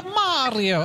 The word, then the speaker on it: Mario: It's not Mary with an O. – Yeah, Mario.